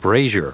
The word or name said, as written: brazier